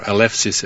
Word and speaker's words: Eleusis: What I didn't mention, what I found out during my visit in Eleusis, or Eleusis as the Greeks pronounce it, was the number of initiates.